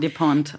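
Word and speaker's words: Despentes: Despont. Virginie Despont. – Despont.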